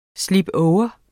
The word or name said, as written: slipover